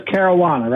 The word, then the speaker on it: Caruana: And the way I get around people mispronouncing it, I say Caruana. It rhymes with marijuana. – Ah, that's a good one. I always say Kowalczyk like a checkbook. So your last name is Caruana, right? – That's my last name is Kowalczyk.